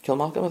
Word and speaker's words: Kilmacolm: Kilmacolm, I think I pronounced that right.